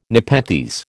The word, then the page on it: nepenthes: American English